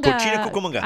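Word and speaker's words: Cocina Cucamonga: is it Cochina Cucamonga. – Cochina Cucamonga. – I always say that wrong.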